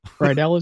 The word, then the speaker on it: Rydell: Anyway, yeah, two helpers, Brady, and I'm going to just say it the way it's spelled, Rydell. – Rydell is correct.